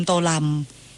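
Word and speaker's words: Tô Lâm: โตเลิมอาจารย์คะเขามีสำเนียงภาคเหนือภาคใต้ด้วยนะคะโตเลิมโตลำ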